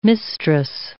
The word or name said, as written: mistress